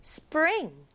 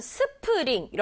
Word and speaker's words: spring: 자칫 잘못하면 스프링 이렇게 세 박자로 발음할 수 있지만 글자가 이렇게 많아도 영어에서는 한 박자예요. 그래서 친구들이 스프링 아니고 spring, spring 이렇게 한 박자에 쏙 집어넣어주는 게 중요해요. S 그쵸?